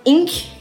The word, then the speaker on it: Ng: É, a próxima que eu pensei foi a Celeste Inc., pelo que a Diana me ensinou como falar o nome dela, porque o sobrenome se escreve NG, muito minimalista.